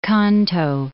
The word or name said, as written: Kanto Plain